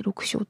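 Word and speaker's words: 緑青: なんと、鴨の橋に紫外線を照射すると、毛皮が六小色に光る、これ青緑と書いて六小って読むらしい、という特徴が発見されたのです。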